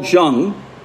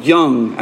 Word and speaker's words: Jung: And so he said he likes to quote Carl Jung, who was, or Carl Jung, I think it's pronounced with a Y.